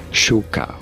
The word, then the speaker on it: Suga: In Korean, this is usually said as Suga. Suga was Suga. More of a K.